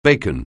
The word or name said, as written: bacon